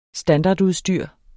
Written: standardudstyr

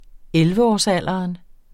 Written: elleveårsalderen

